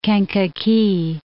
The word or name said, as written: Kankakee